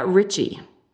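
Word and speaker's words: Ricci: So the last artist before we head to campfire is Lydia Ritchie. I think it's pronounced that right. It's R-I-C-C-I.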